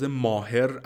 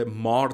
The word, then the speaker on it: Maher: فامیلیش ماهر نوشته میشه M A H E R ولی از تلفظ ماهر اصلا خوشش نمیاد و همیشه روی مار تاکید میکنه